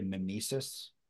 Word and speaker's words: mimesis: It's called historical or literary mimesis. – Spell it. – Mimesis. M-I-M-E-S-I-S. Mimesis.